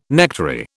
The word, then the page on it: nectary: British English